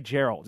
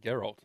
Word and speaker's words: Geralt: Now I say Gerald. Is it Gerald or Gerald? – I pronounce it Geralt. – You'd pronounce it Geralt. Yeah, I think that's that's it's Geralt because I was having this argument with Kate the last night.